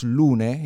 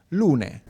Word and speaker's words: Lunae: Yeah, but that seems obvious to you, but how do you spell it? – L-U-N-A-E. – But you pronounce it? – You pronounce Lune. – Lune, okay. – Because it's a Latin name.